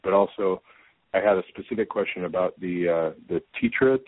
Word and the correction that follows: titer: test. Is that how that's pronounced? – Titer. – Titer test.